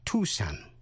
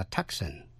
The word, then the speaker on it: Tucson: Thí dụ như trong bài trước, quý vị còn nhớ tên tiểu bang K-A-N-S-A-S đọc là Kansas. Nhưng tiểu bang A-R-K-A-N-S-A-S có âm S gâm đọc là Arkansas. Tên thành phố Tucson, thủ phủ của tiểu bang Arizona, đọc là Tucson. Tucson, chứ không đọc là Tucson.